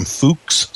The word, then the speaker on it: Fookes: And if you do a search on Google for just Easy Thumbnails, it's available from Fuchs Software, and it's spelled F-O-O-K-E-S, Fuchs Software.